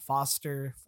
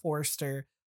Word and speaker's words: Foster: Also, I called Scott Foster, Forster.